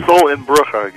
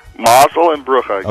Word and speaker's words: mazel and brucha: Contracts of millions of dollars are concluded by only a handshake and the Hebrew Yiddish mazel and bruka, which means luck and blessing. Did I say that right? – Yeah, pretty close. – So rather than being written... – Mazel and Brucha, yeah.